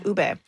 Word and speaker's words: ube: Ube.